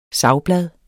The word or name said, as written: savblad